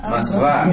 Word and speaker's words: Moskva: Moskva, that's right. Moskva. How would you transliterate this into English? – Moscow. – M-O-S-C-O-W.